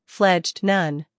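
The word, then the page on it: fledged: uk fledʒd; en-us fledʒd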